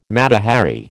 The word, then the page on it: Mata Hari: American English